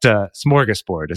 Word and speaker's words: smorgasbord: Some people put a G at the end. That's wrong. – It's Borg. – Yeah, I've seen that one too online, but it's board. So B-O-R-D is the board. And then smorgas is spelled with some fancy little symbols over the letters that we don't normally have in American English. But smorgasbord is how it's said.